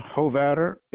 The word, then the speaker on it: Hovatter: Is Ken Hovatter, H-O-V-A-T-T-E-R.